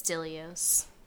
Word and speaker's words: Dilios: It is Dilios.